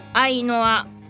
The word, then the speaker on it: AYNOR: なんだろうな、なんかいろんな話しようと思うのにさ、結局こうなんか一個この話したいって思ったら結構長く喋ってしまうという相変わらずな感じで、あっという間にエンディングでございますが、新しいバンド、アイノア、 AYNOR と書いてアイノアと読みます。